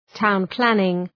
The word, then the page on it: town planning: ,taʋn’plænıŋ